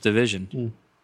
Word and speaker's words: DVSN: it's spelled DVSN. – That's me. – It's pronounced Division.